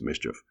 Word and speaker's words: MSCHF: Sneaker giant Nike has filed a lawsuit against a company called MSCHF, which I will pronounce Mischief.